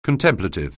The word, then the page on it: contemplative: anglais